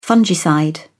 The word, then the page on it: fungicide: British English